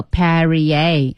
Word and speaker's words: Perrier: 注意读音叫 Perrier.